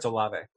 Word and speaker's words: Olave: I was calling him Olave for the longest time, and then I heard people saying Olave. And I was like, oh shoot, I said the wrong thing this entire time. People are the worst. – I am 99% sure it's Olave. – Yes, it's spelled like it's Olave.